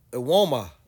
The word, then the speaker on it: Evbuomwan: Ewoma. Oh, man, it's Ewoma? Not that bad. Ewoma. – Yeah. So maybe not so bad as it's Evbuomwan. – Well, the good thing is a lot of silent letters.